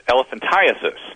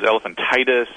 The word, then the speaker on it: elephantiasis: But the biggest thing that seemed to get Mike's attention was the actual name of the condition that he has is elephantiasis. – Uh-huh. – Right. – And somehow, I think maybe it was you guys, too, thought it was elephantitis and all. – I have heard people mispronounce that my whole life. – I've mispronounced it my whole life.